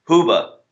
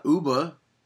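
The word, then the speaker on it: Hoobastank: Ubastank has like two good songs. – You're not even pronouncing their name right. – Ubastank. – Huba? – Huba. – Yeah, it's not Uba.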